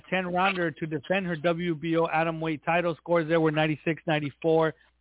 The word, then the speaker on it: Atomweight: Ayaka Miyao scored a majority decision over Eri Matsuda in a 10-rounder to capture the vacant IBF Atomweight title.